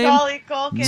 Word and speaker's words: Macaulay Culkin: Macaulay Culkin.